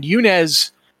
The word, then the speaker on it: Yunez: And Yunez, the name Yunez is actually a phonetic pronunciation of UNES, which is United Nations Earthship.